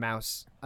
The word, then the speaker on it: Maus: It's spelled M-A-U-S. – Yeah, like German mouse.